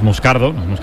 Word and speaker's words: Moscardo: Como bien ha pronunciado Unai, es Moscardo, es brasileño.